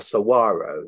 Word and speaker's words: saguaro: and then to see those John Wayne style saguaro cacti or saguaro is actually how you say it.